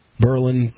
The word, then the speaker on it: Berlin: in Berlin specifically. – Berlin, whatever. Berlin. – Everything in New Hampshire, and probably this applies to everything in New England, you put the emphasis on that first syllable.